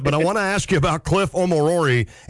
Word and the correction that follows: Omori: And I know I said that wrong. Omorori, how do you say it? – Yeah, it's pretty funny, actually. In the game the other day, it was mispronounced like 12 different times, and actually one of the local beat writers wrote an article about how to properly pronounce his name today, but it's Omori. – Omori. Okay.